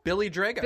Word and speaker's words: Drago: Billy Drago.